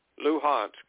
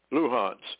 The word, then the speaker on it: Luhansk: Luhansk. Luhansk.